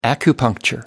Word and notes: acupuncture: American English

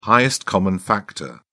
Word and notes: highest common factor: British English

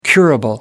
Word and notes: curable: American English

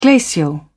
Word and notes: glacial: British English

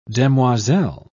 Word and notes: demoiselle: British English

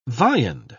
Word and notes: viand: British English

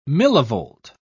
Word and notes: millivolt: British English

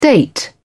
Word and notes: date: British English

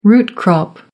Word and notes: root crop: American English

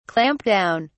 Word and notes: clamp down: British English

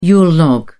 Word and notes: yule log: British English

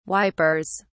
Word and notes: wipers: British English

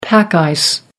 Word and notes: pack ice: American English

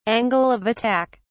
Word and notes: angle of attack: British English

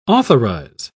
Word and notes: authorise: British English

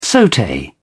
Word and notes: saute: British English

